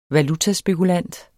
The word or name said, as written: valutaspekulant